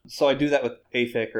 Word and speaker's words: AFICK: AFIC.